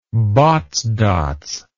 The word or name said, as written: Botts dots